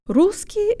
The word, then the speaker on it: русский: Let's start with the second syllable of the masculine, русский. Jaw slightly forward, tip of the tongue behind the bottom teeth, lips rounded and protruded. Ки. Ки. English